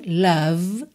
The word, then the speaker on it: love: Percebe que eu termino só na letra V. Love. E o O não tem um som de O, não é love. Tá? E não é love, porque esse E final não é pronunciado, tá pintadinho de cinza aí, tá?